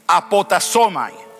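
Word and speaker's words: apotasomai (ἀποτάσσομαι): Esa palabra renunciar en griego significa, se pronuncia así, apotasomai o apotasimi.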